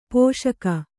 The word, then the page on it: ಪೋಷಕ: pōṣaka